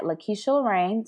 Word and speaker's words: LaKesha Lorene: You can follow me on Instagram at LaKesha Lorene.